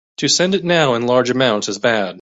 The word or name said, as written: amounts